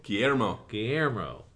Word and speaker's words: Guillermo: Dog Eaters number one from Antarctic Press is written by Malcolm Wong with art by Guy Elmer. I know how to pronounce that. Help me. – Guillermo. – Jeez. Guillermo Angel.